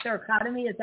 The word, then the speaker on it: thoracotomy: I'm telling you, I had a thoracotomy.